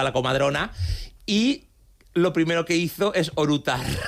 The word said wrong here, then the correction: eructar: Con orutar es eructar.